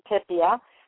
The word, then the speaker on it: Pipia: And I think Rev Patty Pipia. And my last name is spelled P-I-P-I-A. It's like E-I-E-I-O, except for it's P is in bow, I, P is in pretty, I-A.